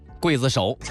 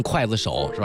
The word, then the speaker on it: 刽子手: 啊，刚才有很多朋友一直在说刘德华刽子手啊，读成筷子手是吧，啊，这其实你考古华语流行的音乐史，冰雨这首歌的讨论一定非常高啊。